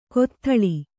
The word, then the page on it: ಕೊತ್ಥಳಿ: Kannada